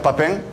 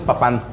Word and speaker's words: Papin: Pero también hubo un balón de oro, Jean-Pierre Papin, un delantero que jugó en Milán. – Sí. – Después. – Bueno, aquí le decíamos Papán. – Ah, Papán. No sabíamos cómo se pronuncia.